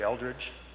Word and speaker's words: Eldridge: Terry Eldridge, T-E-R-R-Y, E-L-D-R-I-D-G-E.